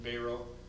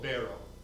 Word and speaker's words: Beyro: And I don't know if I don't talk to Rick about his conversations with Beiro. I think that's how he pronounces it. It's spelled B-E-Y-R-O, Beiro.